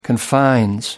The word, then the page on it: confines: American English